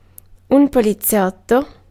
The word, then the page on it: poliziotto: /pɔ.li.ˈʦjɔt.tɔ/